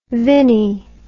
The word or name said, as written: vini